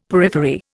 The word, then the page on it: periphery: Indian